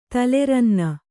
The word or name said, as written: ತಲೆರನ್ನ